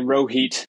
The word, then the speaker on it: Rohit: But such is life for Rohizi, a.k.a. Rohit, because that's not only how people mispronounce my name, that's my new alter ego when it comes to my footwear collection.